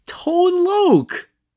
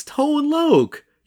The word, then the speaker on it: Tone Loc: Tone Loc. That's Tone Loc.